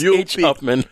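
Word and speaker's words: Upmann: Huffman? – Huffman. – No, it's H Upman.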